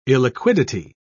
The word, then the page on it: illiquidity: British English